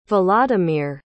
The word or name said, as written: volodymyr